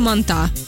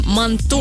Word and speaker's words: манту: Ее очень сильно удивляет, когда приходит и говорит, «Мы на прививку реакция манта». – Манта? – Ну, правильно, реакция манту.